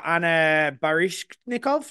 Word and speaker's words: Anna Baryshnikov: Um, you've got Kristen Stewart, you've got Katy O'Brian, you've got Anna Baryshnikov. Yeah, I said it right.